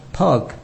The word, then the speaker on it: pygg: Rather, it comes from the Old English from around the 15th century from a type of orange clay that was spelled P-Y-G-G and pronounced pug. By the 18th century, the word P-Y-G-G now sounded the same as the word for the animal pig.